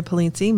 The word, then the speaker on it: Polizzi: So my name is Dr. Allessandria Polizzi.